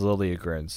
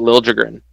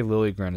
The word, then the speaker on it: Liljegren: And I think he says Liljegren, so I think that would be. I don't think Swedish guys are big on the the hard J sounds.